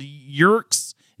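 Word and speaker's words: Yeerk: Yerks. Is it Yerks or Yerks? – I always hear Yerk like rhymes with jerk.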